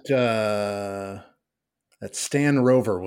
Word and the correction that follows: Royer: Well, see, you're, you're cheating and you mispronounced his name because it's Stan Royer.